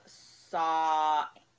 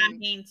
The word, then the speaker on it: Samhain: It's called Saw-an is how you pronounce it. Saw-an. – Pronounce it. But it's spelled S-A-M. It, yeah. S-A-M-H-A-N. But it's pronounced Saw-an or something. Whatever. Saw- pronounce it however you need to. – Pronounce it however you need. – I thought it was Samhain too. And then later when I was looking, it showed the pronunciation. And I was like, oh, that looks nothing like the word.